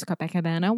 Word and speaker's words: Copacabana: It's not going to be as good as Copacabana war- fireworks.